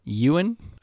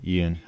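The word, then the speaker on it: Yeun: Hopefully, I'm rooting for Steven Ewan, I think is the way to pronounce his name, from Minari. – Ewan.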